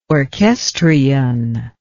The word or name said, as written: orchestrion